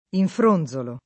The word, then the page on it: infronzolare: lingua italiana